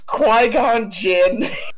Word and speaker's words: Qui-Gon Jinn: There's probably legit a nerd that's on the verge of killing himself because you're saying Qui Jing Long. – What? Okay, how do you pronounce what is it? What is Liam Neeson's name? – It's Qui-Gon Jinn.